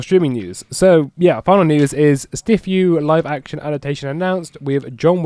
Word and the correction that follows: Sifu: Not the name of the guy, the Sifu, not Stifu.